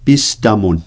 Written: pistamun

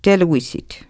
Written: teluisit

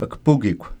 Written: kpukikw

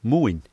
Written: muin

